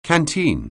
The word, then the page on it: canteen: anglais